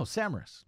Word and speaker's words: Samaras: Is that how you pronounce his name? I think it is. – Costa Samaras. – Oh, Samaras. – Yes.